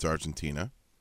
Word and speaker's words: Argentina: Said, hey, numbnuts, it's Argentina, not Argentinia. – Mm-hmm. – So I'm aware it's Argentina, as in don't cry for me.